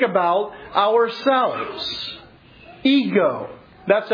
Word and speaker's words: ego: It's pronounced ego in Greek.